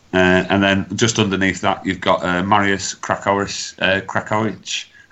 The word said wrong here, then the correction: Krakowczyk: I won't mind, but I've even asked Rob how to pronounce that one, and he sent it me earlier, and I've still struggling with the Polish. – Yeah, Krakowczyk. There we go.